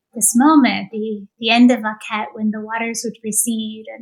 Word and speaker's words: akhet: Am I pronouncing this correctly? Akhet? – I think that's right. You know, we're not actually sure how it's pronounced because the vowels have been lost to history. It's just the hieroglyph from which we've reconstructed the word, just with its consonants.